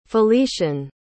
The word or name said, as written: felician